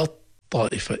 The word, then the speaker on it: الطائفة: قال الطائفة، التاء مكتوبة لكن لا تنطق لأننا غيرنا التاء أبدلناها إلى طاء وأدغمناها في الطاء، فصار النطق بطاء مشددة.